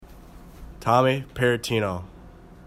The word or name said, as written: Parrottino